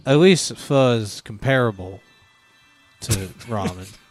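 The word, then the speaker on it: phở: Eat pho while we... – It's pronounced pho.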